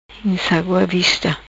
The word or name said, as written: Insagua Vista